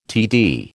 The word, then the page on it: TD: American English